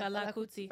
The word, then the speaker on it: Fela Kuti: How do you say it? – Yeah, Fela Kuti.